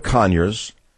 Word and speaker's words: Conyers: Then Representative Conyers, emphasis on the first three letters of his last name, introduced Bill H.R.